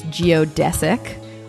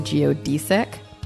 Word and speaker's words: geodesic: Okay, so the word this week is another frustrating word that is pronounced two different ways. I'm going to go with the consensus pronunciation, which is geodesic. Although I personally would like to say geodesic, which you can say too.